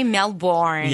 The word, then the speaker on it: Melbourne: Am I saying it correctly? – Yeah, you're saying it correctly. – No, I'm not. But okay, that's fine. – You didn't say Melbourne. – Yeah, so Melbourne. – That's good. – You're doing well.